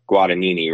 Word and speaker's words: Guadagnini: I mean, you watched, and I don't want to say his name wrong, but Guadagnini, right? On the gas. – Yeah, right? – Yeah. Yes.